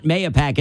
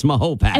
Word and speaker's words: Mahopac: It's not Mayapac, it's Mahopac.